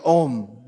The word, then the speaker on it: Om: Well, first of all, we have the first word is Om. Now, if we first word of Srimad Bhagavatam is Om. Now, to understand that, first that just this one syllable. Oh, actually, it's three syllables.